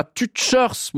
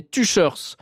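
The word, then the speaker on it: Tüscherz: et un auditeur me dit que Tuchers, ça se prononce pas Tuchers mais Tuchers, voilà, il me dit que c'est Daucher en français mais que personne ne dit ça.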